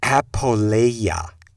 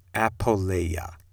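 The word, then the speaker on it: Apoleia (ἀπώλεια): is the destination is I can get my Greek pronunciation so messed up. Sorry about that. It's Apoleia. Apoleia.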